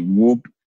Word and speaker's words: Wobb: Some people say it's WOB. – WOB, yeah. – Whoop. Yeah. Yeah. And then I have to tell, it's like W-O-B-B. It's like Bob with a W.